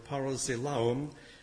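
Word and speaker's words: parazeilaon: Now, the phrase to provoke to jealousy is those four English words make up one Greek word, parazelaon. It's spelled P-A-R-A-Z-E-I-L-A-O-N, P-A-R-A-Z-E-I-L-A-O-N.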